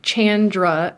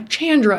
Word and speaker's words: Chandra: So my name is Chandra, and how I tell people to pronounce it is think of like Jackie Chan. And for some reason, that little tip, everyone's like, oh yeah, Chandra.